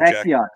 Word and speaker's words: Czech: And he just recently come off a World Juniors silver medal with Team Chechnya, or however we're pronouncing it, Team Czech.